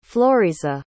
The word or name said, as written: floriza